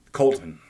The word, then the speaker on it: Coulton: Coulton. Coulton. Okay, yeah. Pretend there's no you in it.